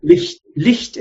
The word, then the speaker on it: Licht: Now, if you know the equivalent German word, which is almost identical, but has not a G-H, but a C-H, and the C-H is pronounced Ch. – Or Knecht. Knecht, right? – Licht is light. Instead of L-I-G-H-T, you have L-I-C-H-T. – Uh-huh, yeah. – Night is Nacht. G-H-T turns into C-H-T.